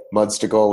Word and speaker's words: Mudstacle: And then for you guys, there's a website called Mudstickle. Have you heard of it? – I've not. – Okay, Mudstickle, which is spelled like it sounds, right? Like popsicle or what, MUDSTACLE, and they've got a great calendar.